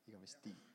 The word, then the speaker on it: igavesti: Igavest. Did I say that right? – Igavesti. – Igavesti. Oh, good.